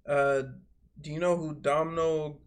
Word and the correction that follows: Domhnall: Gleason is? I don't know if that's how you pronounce it. Domhnall Domhnall Gleeson. – Donald Gleeson. – No, he Domhnall.